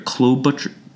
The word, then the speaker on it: Klobuchar: Senator Klobuchar.